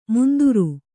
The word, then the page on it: ಮುಂದುರು: Kannada